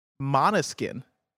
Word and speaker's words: Måneskin: Uh, Måneskin is pronounced monoskin.